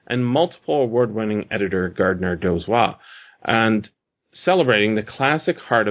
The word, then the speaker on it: Dozois: Dozois.